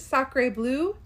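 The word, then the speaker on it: Sacré bleu: I'm not going to pronounce any of these correctly, but he says, Sacre bleu. – Yeah, you just pronounce that right.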